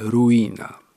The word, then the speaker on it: Rowena: Lady pisze się, proszę Państwa, Rowena, a czyta się, jak się okazuje, Ruina.